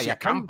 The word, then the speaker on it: Siakam: ¿Siakam? – Sí, Siakam, Pascal Siakam, claro.